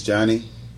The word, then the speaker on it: Johnny: My name is Johnny L. Anthony, Sr., J-O-H-N-N-Y.